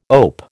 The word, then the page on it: OAP: American English